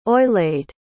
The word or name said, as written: oeillade